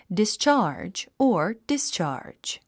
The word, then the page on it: discharge: distʃάː r dʒ; ディスチャージ